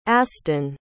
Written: astin

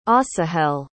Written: Asahel

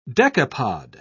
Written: decapod

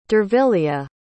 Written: dervilia

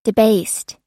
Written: debased

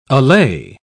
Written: allay

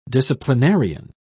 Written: disciplinarian

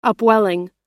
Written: upwelling

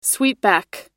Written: sweepback

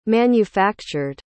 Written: manufactured

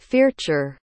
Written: fearchar

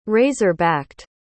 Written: razor-backed

